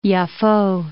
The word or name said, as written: Yafo